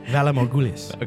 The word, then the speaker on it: Valar Morghulis: Valar Morghulis.